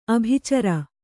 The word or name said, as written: ಆಭಿಚಾರ